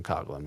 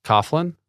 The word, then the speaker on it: Coughlan: Like, it's G-H. At least say, I'm fine with Coughlan, because it's phonetically spelled that way. It's spelled cough and then L-A-N. – Uh-huh. – Like, but Coughlan, like, why are we just going silent for all of the letters until L?